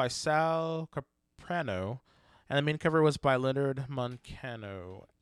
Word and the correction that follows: Manco: And then the backup story was by... – Manco. – Thank you. the backup story was by Ken Pontac. The art... I said it right. – I know. – Art by Leonard Manco.